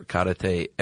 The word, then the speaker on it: karate: Because this is going to be a nice overview and a nice introduction, but please do not think we are karate experts or masters, because we are not. – Let's begin. – Yes. – So, Chuck, it turns out, and you're pronouncing it correctly, karate.